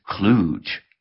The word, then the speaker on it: kludge: We note that kludge, the kludge spelling without the D, better reflects its pronunciation, and it rhymes with the word huge, not usually with the word fudge.